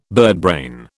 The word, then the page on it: birdbrain: British English